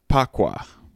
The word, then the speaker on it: bagua: And then there's something called pagua, which is spelled B-A-G-U-A. – B-A-G-U-A.